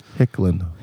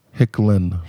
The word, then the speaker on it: Hicklen: Hicklin. – Hickland? – Hicklin. – Hicklin. Spell it. – People always put a D in there. – Yeah. – H-I-C-K-L-E-N.